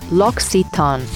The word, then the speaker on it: L'Occitane: It's very confusing because you have two C's in a row, which is not something you typically see in English language. So a lot of people don't know how to really pronounce it. So it is pronounced L'Occitane.